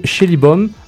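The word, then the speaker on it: Schällibaum: Donc ils ont un entraîneur-chef, un Suisse, Marco Schillibom, Le A à deux points se prononce comme E en allemand.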